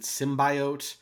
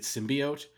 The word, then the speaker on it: symbiote: Did you notice that in the trailer she pronounced it symbiote? Yes. And then I feel like they must have gone back and redubbed every instance of that word to make it symbiote.